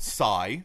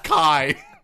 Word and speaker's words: CI: Also called Psy. – Yeah. – Because it's soft C. – It's a soft C. – For once, it's a hard C, and it's Kai.